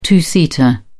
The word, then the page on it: two-seater: British English